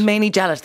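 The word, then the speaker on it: Mainie Jellett: I'm pronouncing that correctly, am I? – Manet Gellet. – Manet Gellet, there you go.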